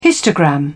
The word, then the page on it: histogram: British English